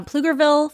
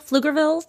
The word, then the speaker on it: Pflugerville: Starts with a PF, so I'm going to assume that the P is silent there in uh, Pflugerville, Pflugerville, Texas, explaining that he was racist to a group of students.